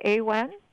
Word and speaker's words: Awen: Well, I know that you are the author and founder of am I pronouncing it correctly, AWEN? – It's the nonprofit. It's called Awen Grove, and Awen is the same meaning as the Tao. It's just an ancient Celtic term.